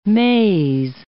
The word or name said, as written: maize